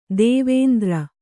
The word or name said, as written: ದೇವೇಂದ್ರ